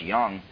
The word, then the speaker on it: Jung: it's spelled like Jung, but it's actually pronounced Jung,